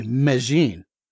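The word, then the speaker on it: Medellín: Oh, yeah, because the double L is a J instead of a Y, and they really kind of slur their syllables together. So to them, the name of the town is, as I'm saying it, Medellin, actually Medellin.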